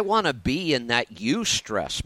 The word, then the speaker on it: eustress: and it's spelled E-U-S-T-R-E-S-S, eustress.